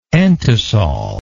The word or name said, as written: entisol